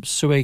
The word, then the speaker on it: Xue: By the way, they'll be getting their coffee from Sue Cam Coffee, which is in the market on Sundays, Thursdays, and we're here Fridays now too. And Sue is spelt X-U-E, so it's X-U-E Cam Coffee, and it's very good.